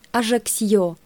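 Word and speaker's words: Ajaccio: По-французски же Корсика как-никак часть Франции. В связи с отсутствием в языке Мольера всяких там черепков, черенков, чекушек, чертиков и прочей чечепухи, произносится отличное словечко «ажаксьё».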